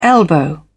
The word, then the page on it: elbow: British English